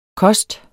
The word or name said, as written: kost